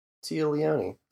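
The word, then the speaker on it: Téa Leoni: Don't know how to pronounce her name. – Tia Leone. – Tia Leone? – Sure.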